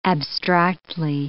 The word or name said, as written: abstractly